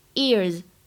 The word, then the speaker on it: ears: 首先，第一个，耳朵这个单词呢当中包含了一个双元音 ear ear 要读对。那么美音的话呢，整个单词读作 ear，后面加了一个 s 发 z ears。